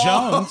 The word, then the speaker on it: Jonze: That's spelled J-O-N-Z-E. – Yeah, but it's still Jones.